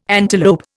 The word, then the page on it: antelope: Indian